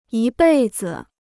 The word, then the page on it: 一辈子: yī bèi zi